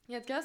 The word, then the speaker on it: Yatka: Et Yatka, comment ça se prononce ? – Yatka, c'est correct, c'est bien.